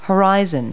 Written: horizon

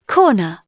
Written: corn

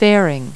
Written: fairing

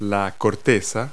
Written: rind

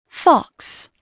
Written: fox

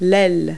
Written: wing

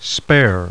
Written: SPARE

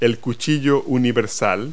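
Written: utility knife

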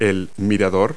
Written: gazebo

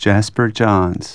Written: Johns

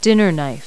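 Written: dinner knife

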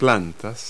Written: plants

